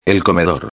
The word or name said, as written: el comedor